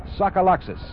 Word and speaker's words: Tsagalakis: He spells his name T-S-A-G-A-L-A-K-I-S and pronounces it Sokoloxis.